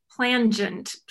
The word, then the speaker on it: plangent: How do you say it? – Plangent, P-L-A-N-G-E-N-T.